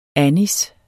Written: anis

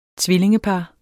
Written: tvillingepar

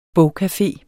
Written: bogcafe

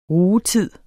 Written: rugetid

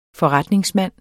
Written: FORRETNINGSMAND

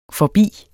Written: forbi

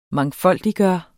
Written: MANGFOLDIGGJORTE

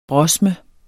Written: brosme

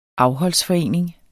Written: afholdsforening

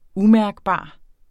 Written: umærkbar

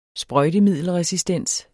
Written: sprøjtemiddelresistens

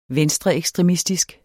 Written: venstreekstremistisk

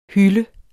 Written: hylde

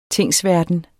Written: tingsverden

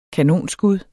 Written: kanonskud

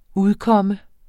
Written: udkomme

